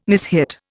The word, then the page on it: mishit: Indian